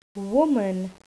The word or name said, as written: woman